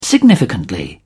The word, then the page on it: significantly: British English